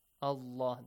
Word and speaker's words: الله: The word Allah, because we have a fatha before. It has to be pronounced heavily, yes? Allah, the lam has to be pronounced heavily.